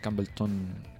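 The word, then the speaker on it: Campbeltown: Campbellton, que es como lo pronuncian allá.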